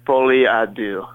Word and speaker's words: Folie à Deux: So, the new record is called, help me with the pronunciation, because my French is bad, My French is bad, too. It's Folie à Deux.